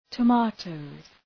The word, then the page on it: tomatoes: tə’meıtəʋz